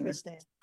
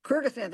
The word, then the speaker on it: Kyrgyzstan: embassies in Nicaragua, Grenada, Somalia, Uzbekistan, pardon me if I'm mispronouncing Kyrgyzstan. – Kyrgyzstan, thank you.